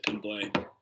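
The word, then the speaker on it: Pimblett: Yeah, Paddy Pimblay, that's how you say it, Pimblay.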